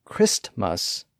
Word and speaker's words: Christmas: One scenario where that tended to happen was in words with two syllables where the first syllable ended in ST. That T sound was often lost in the transition from the first syllable to the second syllable. That's how Christmas became Christmas. In many dialects, postman became postman, and waistcoat became waistcoat.